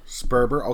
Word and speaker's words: Sperber: Sperber.